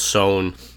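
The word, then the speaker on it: Saône: Apparently it's Saun. – Saun. The Sone River. Okay, yeah. There you go. – I guess it rhymes with the Rhone.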